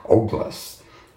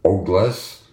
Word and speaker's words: Ogles: In a now-depleted ex-post published last week, rapidly anti-gay representative Andy Ogles. I think he pronounces his name Ogles. It's O-G-L-E-S.